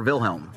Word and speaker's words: Wilhelm: It's a Wilhelm or Wilhelm? – Wilhelm. – Wilhelm, it's German.